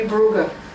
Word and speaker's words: Brugge: Depending on which language you want to pronounce it in. – Right, one's French and one's Bruges would be the French and the Flemish would be Brugge.